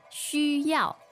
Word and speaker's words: 需要: 需要吗? – So this next word is the verb, right? – Yes. 需要. X, U, first tone. Y, A, O, fourth tone. 需要.